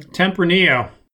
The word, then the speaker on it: Tempranillo: Tempranillo.